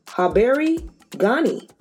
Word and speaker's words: Habari Gani: and it's pronounced Habari Gani. It's really easy. Habari Gani. That means how are you in Swahili. Habari, H A B A R I, and Gani, G A N I.